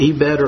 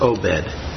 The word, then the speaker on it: Obed: And one of the things that touches me so much from this Moabite woman who had no claim on God, Obed, pronounce him however you want, Obed or Obed, he begets Jesse.